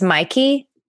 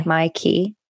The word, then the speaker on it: Mykey: His name is Mikey, but it's spelled M-Y-K-E-Y. – Okay. – Mikey.